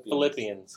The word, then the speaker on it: Philippians: The Philippines? – Philippians. Philippians.